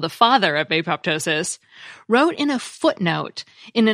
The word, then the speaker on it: apoptosis: Since it's the ptosis part that means to fall, and it's pronounced without the P, even though it's spelled with a P, P-T-O-S-I-S, Kerr suggested that his discovery should be pronounced without the P, apoptosis.